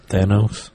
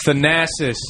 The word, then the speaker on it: Thanasis: How do you say that? – Thanos. – It's not Thanos. Talking about Thanos. – Thanasis. – Thanasis. – Thank you.